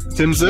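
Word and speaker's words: Tim Tszyu: Tim Sue. – You got it right, Tim Sue. Oh, yeah, kind of like Sue, yeah.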